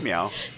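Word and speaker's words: Miao: I always just said Mao when I read his name. How did you say it? Did you look up how to say it? – I said it in my head, so it was just this guy. – Wang M. Like, yeah, it's Meow. Wang Meow.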